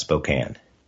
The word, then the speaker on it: Spokane: It's pronounced Spokane. You did pretty well.